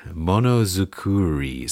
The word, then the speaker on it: monozukuri: Monozukuru. Monozukuri. So Monozukuri. – Yep.